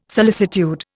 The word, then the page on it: solicitude: Indian